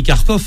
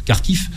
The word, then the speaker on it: Kharkiv: Ils avaient pris Kharkov, hein, Kharkiv, pour le prononcer en ukrainien, et ils sont repartis.